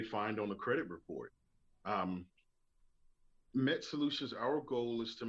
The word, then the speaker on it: MMET: It's actually pronounced MET. – Oh, sorry. – Oh, no problem. No problem. Pronounced MMET, MET.